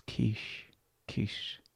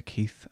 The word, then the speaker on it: Keith: It's Quiche. The TH is like a sh. Quiche. Or Keith. I like to say Keith, actually.